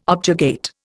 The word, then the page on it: objurgate: Indian